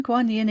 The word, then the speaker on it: Guanyin: And you mentioned Guanyin again. I noticed that you pronounce Guanyin rather than Quanyin.